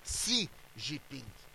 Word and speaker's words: Xi Jinping: Mas o Putin, diz o Xi Jinping, para dizer a verdade, não é Xi, se escreve com X, mas se diz Xi Jinping.